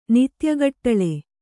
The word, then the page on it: ನಿತ್ಯಗಟ್ಲೆ: kannada